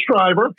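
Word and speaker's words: Schrier: Shriver? How do you say her last name? – It's Shriver. It starts with spelling just like mine. S-C-H-R-I-E-R, I believe it is.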